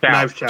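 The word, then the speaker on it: Knives Chau: Did we say Knives Chang? – Knives Chow. – Chow. That's what. – Yeah, I was saying it wrong.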